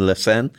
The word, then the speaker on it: La Seine: Now, the Olympics are taking place in Paris, and they're going to have the opening ceremony on La Seine.